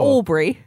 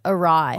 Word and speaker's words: awry: Not Aubrey. – No, awry. – Yeah, it's pronounced awry.